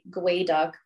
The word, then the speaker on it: geoduck: And that's been an issue at the restaurant is people don't know how to pronounce it since it's spelled geoduck. So a lot of people are just like, I'm just not gonna get that thing because I don't know if that's actually how you say it or not. – Right, right. – So gooey duck is actually gway duck, which is the native tongue for what the species is.